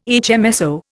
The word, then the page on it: HMSO: Indian